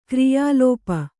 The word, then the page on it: ಕ್ರಿಯಾಲೋಪ: kriyā lōpa